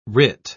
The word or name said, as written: writ